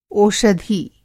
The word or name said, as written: ओषधि